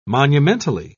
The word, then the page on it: monumentally: British English